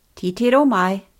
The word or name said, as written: titiro mai